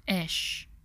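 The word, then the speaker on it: esh (אש): In Hebrew, the word for fire is that Hebrew letter pronounced as esh, while the word for man pronounced as ish, and the word for woman is pronounced as isha.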